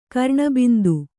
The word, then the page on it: ಕರ್ಣಬಿಂದು: kannada